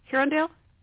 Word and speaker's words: Herondale: However, she can pick her favorite book boyfriend, Will Herondale, that's what. Did I say that right, Kelly? Herondale, yes, that's right. – Herondale, okay.